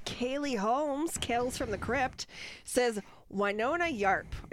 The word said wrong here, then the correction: Earp: Yarp? Arp? – Winona Earp? – Earp? Earp! – Like Wyatt Earp? – Wyatt! Oh, that makes sense.